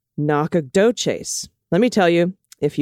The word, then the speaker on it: Nacogdoches: The Texas sister town is pronounced Nacogdoches.